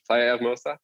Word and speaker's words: Hermosa: Don't need to pronounce that H, Playa Hermosa.